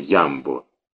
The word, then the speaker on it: Ямбо: Впрочем, у нас слово «Ямбо» произносили с ударением на первый гласный «Ямбо».